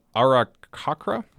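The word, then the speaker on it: Aarakocra: Yeah, so you have the Goliaths, the Genasi in all forms of the Genasi, the Dark Gnomes, and the, I think it's pronounced Arakakra.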